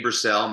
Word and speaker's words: Brassell: Like you said, I'm Dr. Danny Purcell. My last name's really easy to remember how to spell. It's spelled like bras, cell.